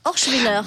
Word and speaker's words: Orschwiller: Où ça ou ça Orschwiller.